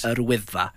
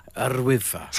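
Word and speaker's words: Yr Wyddfa: Yrwyddfa. – Yrwyddfa.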